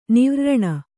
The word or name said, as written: ನಿವ್ರ್ರಣ